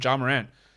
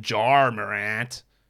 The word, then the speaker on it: Ja Morant: Someone criticized my pronunciation of Ja Morant. Josh says it like J-A-R. Honestly, how else are you supposed to say Ja Morant? How else would I say it? I'm not saying Jar Morant.